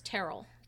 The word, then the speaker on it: Terrell: My maiden name was Terrell, T-E-R-R-E-L-L. And my family pronounces it Terrell.